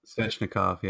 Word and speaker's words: Svechnikov: Svechnikov? Is that right? – Svechnikov, yeah.